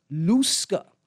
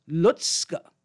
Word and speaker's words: Lusca: It's not pronounced Lusca. It's pronounced Lutzka, as if there's a T in there, right? Lutzka, that's the proper name.